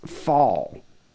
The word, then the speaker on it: phal: It's pronounced phal, phal, phal.